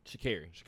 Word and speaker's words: Sha'Carri: Is it Sha'Carri Richardson? – Sha'Carri. Yeah.